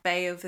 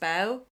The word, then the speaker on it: Badbh: Yeah, so, I mean, I think in that book he would suggest that that Baobh is actually, so it's spelled B-A-D-B-H, and he would say that that's pronounced Bow. Or maybe I read that somewhere else, but I've gone with Baobh, which is, I'll put it in the show notes where that's from. But yeah, I would have thought that the Bow was kind of a Ulster dialect pronunciation, Baobh more of a kind of a Munster direction. Maybe.